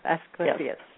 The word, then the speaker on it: Asclepius: Asclepius, yes.